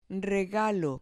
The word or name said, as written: regalo